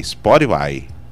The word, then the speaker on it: Spotify: Mas o como é que é que fala certo a pronúncia? É Spotify.